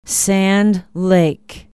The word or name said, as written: Sand Lake